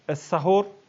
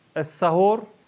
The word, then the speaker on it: es-sahur: Es-sahur. Eğer o sin harfini ötreli, dammeli okuduğumuz zaman başka bir manaya geliyor. Es-suhur. Es-sahur ve es-suhur.